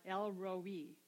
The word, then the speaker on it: El Roi: El Roi is how it's pronounced in Hebrew.